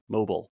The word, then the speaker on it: Mobile: Mobile, right? Yeah. I think they pronounce it Beal. Mobile. Oh, right. Bile.